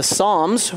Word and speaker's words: Psalms: Let's open up our Bibles to the Psalms with a silent P at the beginning.